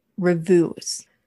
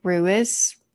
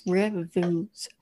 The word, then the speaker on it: Rewis: So um, his name is Terry. He went by Bubba and it's Revus. It's spelled R E W I S. So I was thinking it was like Ruiz, I don't. It's reviews from what the punctuation of his actual sister said.